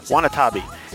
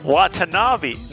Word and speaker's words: Watanabe: Watanabe.